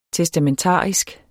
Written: testamentarisk

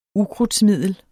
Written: ukrudtsmiddel

